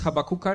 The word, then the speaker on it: Habakuk: How do you say that in Polish? – Habakuk. – Habakuk. Habakuk. Mango. Okay. Habakkuk is the way we say it in Australia. Habakkuk. – Habakkuk should be, really.